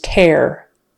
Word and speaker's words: tear: D-O-E-S. Does. Another one-syllable word. Tear, the noun, versus tear, the verb. Tear, with a long E and a vocalic R on the end.